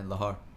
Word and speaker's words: Lahore: talk about Lahore. Did I pronounce that correctly? – Yeah, Lahore. – Lahore.